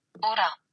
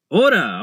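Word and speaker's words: Ora (ура): Y-P-A? IPA? IPA? Is that how you say it? Let's see. – Ora. – Ura, okay, way off. That's a Y and a P and an A. How do you get Ura from that? Ura.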